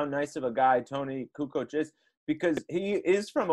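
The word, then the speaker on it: Kukoč: Kukuchuk, man. How about that? – Oh man, Kukoc. It was funny though, watching them confidently say his name wrong.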